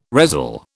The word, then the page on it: resell: British English